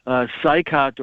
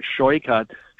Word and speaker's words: Saikat: Connie Chan and this DSA completely just out of his mind AOC type of socialist Marxist Saikat, or he pronounces it Shoykat Chakrabarti.